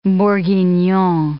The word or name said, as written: bourguignon